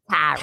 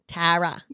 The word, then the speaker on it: Tara: You're New Yorker, so you're like Tara. – Tara. You're like but, a carrot. Yeah. Tara.